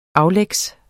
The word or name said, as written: aflægs